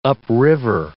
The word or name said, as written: upriver